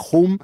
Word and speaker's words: hoon: Y también es una película de primerizo también es su director Kim J. hoon Eh, H-O-O-N, no Hum como yo,